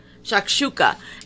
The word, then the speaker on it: shakshuka: Shakshuka, am I saying it right? – Yep. – Shakshuka.